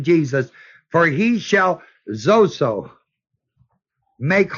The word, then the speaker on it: sozo: The word save is the word zozo, S-O-Z-O, long O.